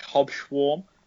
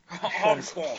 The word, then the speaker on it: Hobsbawm: I say, like, swarm. like swarm, but, like, with a schwarm, like, Hobsbawm. But, like, that's Sean Connery, yeah. – Hobsbawm.